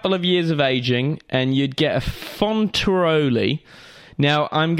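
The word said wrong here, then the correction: Fonterutoli: F-O-N-T. Yeah, so you pronounced it wrong. Should we pronounce it right before you go ahead? – Yeah, let's do it. Let's do that. – So it's Fontarutoli.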